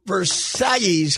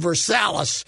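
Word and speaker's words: Versalles: Zoilo Versailles came here in 1961 And it was at least 65 before we stopped calling him Versalis.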